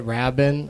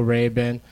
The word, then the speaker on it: Rabin: I said, is it Rabin? And he's like, no, Rabin.